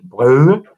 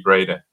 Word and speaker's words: Brede: So for the international audience, my name is, it's true in Danish, you would pronounce it Brede. It's like a sound more than an actual name. In English, it would be Brede.